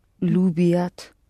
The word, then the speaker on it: любят: Лю, лю, pronounce it soft. Любят.